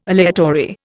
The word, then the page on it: aleatory: Indian